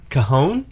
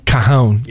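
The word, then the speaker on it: cajón: Cajon? – Cajon.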